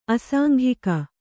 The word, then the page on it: ಅಸಾಂಘಿಕ: asāŋghika